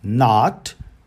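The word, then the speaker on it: nought: Podemos usar também uma palavra bem curiosa, que é o N-O-U-G-H-T, que a gente pronuncia not, quase como se fosse um não em inglês. Not, embora o inglês britânico, eles não têm como se fosse esse agudo no O, eles falam not, certo?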